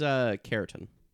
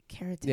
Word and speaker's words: Kjartan: His name is Care, like K-E-R? – It's Keratin. – Keratin. – Yeah, do you want to spell that? – K-J-A-R-T-A-N. – Interesting.